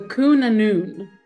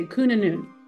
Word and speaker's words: Annwn: It's pronounced in the audiobook, at least, as the Cunanun. – Okay. – It's spelled C-W-N with a little squiggle over the W. Okay. A-N-N-W-N. In the audiobook, they say Cunanun.